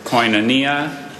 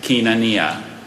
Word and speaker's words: koinonia: There's koinonia and kinonia. – May I have the part of speech? – Oh my God. – Noun. – Can you please repeat the word? – Koinonia, kinonia.